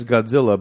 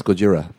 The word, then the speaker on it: Gojira: Because also in the movie they refer to it like us stupid Americans took it as Godzilla, but it was Godzilla or something? – I think it's pronounced Gojira. – Gojira. That's what it was.